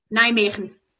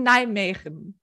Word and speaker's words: Nijmegen: Unsere heutige Gästin ist Dr. Friederike Landau-Donnelly, ich sage mal den ganzen Namen, und sie ist Assistant Professor an der Radboud-Universität in, wie spricht man das aus? Nijmegen. In Nijmegen.